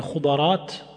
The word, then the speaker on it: خضرات: Donc on peut prononcer également بالضم الأولى وفتح الثانية يعني خضرات مي فتح الأولى وكسر الثانية c'est ce qui est le plus connu